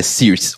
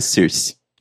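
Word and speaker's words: Saoirse: É Circe, a pronúncia é Circe. Ah, é um nome, é um nome irlandês.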